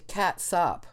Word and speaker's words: catsup: The more I'm looking, it is pronounced catsup. It is not pronounced ketchup. If you spell it catsup, it's pronounced catsup.